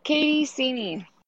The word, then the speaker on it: Katie Ceni: Hey, it's Katie Ceni.